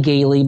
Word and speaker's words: Galie: Uh, my name's Anthony Galie, but it's spelled G A L I E.